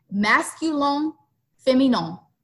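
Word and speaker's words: Masculin Féminin: So moving on to episode 2, Masculin Feminin. Did I say that right? – It sounds good to me.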